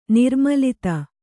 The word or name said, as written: ನಿರ್ಮೂಲಿತ